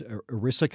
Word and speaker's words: ERISA: And this is self-insured companies known as ERISA companies.